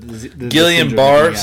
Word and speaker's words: Guillain-Barré: Guillain-Barré syndrome, right? – Guillain-Barré.